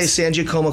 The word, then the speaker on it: Sangiacomo: Say Sangiacomo because they think of Sangiovese.